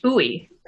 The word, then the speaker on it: Uy: It's pronounced Uy. – Uy, so okay. – For our Southeast and Southwest listeners, it's like the Spanish word muy, which means very, but you drop the M and okay.